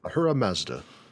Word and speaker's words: Ahura Mazda: Okay, so anyway, so you find it, you get a little standard dungeon, you encounter the creator god of Zoroastrianism, Ahura Mazda, really? Like the car. – Ahura Mazda.